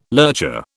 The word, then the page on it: lurcher: British English